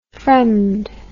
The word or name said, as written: fremd